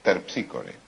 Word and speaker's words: Terpsícore: Dije, es... Terpsícore. – Claro. Es terpíscore. Es terpíscore. Esa es la pronunciación griega, claro.